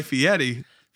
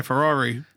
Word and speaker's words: Fieri: So you're talking about Guy Fieri. – Fieri, yes. – Not Guy Ferrari.